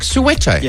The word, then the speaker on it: Soweto: Soweto. – Oh, Soweto. I thought you said Sorrento or Soweto. – No, it's like Soweto.